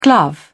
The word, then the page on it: glove: British English